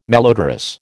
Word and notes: malodorous: American English

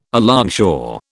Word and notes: alongshore: British English

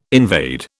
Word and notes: invade: British English

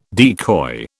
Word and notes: decoy: British English

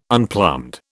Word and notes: unplumbed: British English

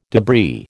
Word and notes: debris: American English

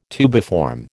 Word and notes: tubiform: American English

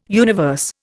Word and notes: universe: Indian

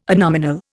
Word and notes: adnominal: Indian

